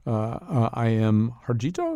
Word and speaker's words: I.M. Harjito: This is, I believe, we're playing original gamelan music composed by Wesleyan artist in residence, I.M. Harjito. Am I saying that correctly? – Yes, and the composer of the other music that you heard of, the treaty, was Neely Bruce.